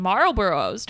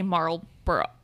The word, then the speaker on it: Marlboro: And Marlboros. Don't you want? I can't say Marlboro. – No, it's Marlboro. It's weird.